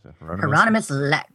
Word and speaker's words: Hieronymus Lex: Hieronymus Lex.